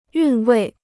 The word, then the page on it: 韵味: yùn wèi